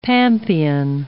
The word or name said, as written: pampean